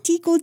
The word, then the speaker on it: Teackle: The father of the newborn baby girl was Tekel Wallace Warfield, and he was the son of a respected surgeon and prominent member of Baltimore society. – His first name was Tekel? – Tekel, Tekel, Tekel. Gucci, Gucci, Gucci. – How do you spell that? – T-E-A-C-K-L-E.